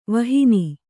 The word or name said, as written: ವಿಹೀನೆ